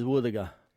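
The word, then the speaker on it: wuthuga: Our local Mulgana word today is woothaga.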